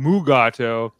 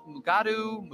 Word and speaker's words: Mugato: Did you know they have alternative pronunciations? Mugatu, Mugutu, and Gumato? Isn't that neat and inconsistent?